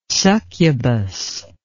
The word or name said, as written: succubous